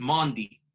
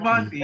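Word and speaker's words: Mendy: I've taken the time to learn the correct pronunciation of my goalkeeper. It's Mandy. Man like Mandy.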